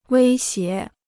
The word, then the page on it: 威胁: wēi xié